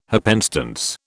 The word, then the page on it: happenstance: British English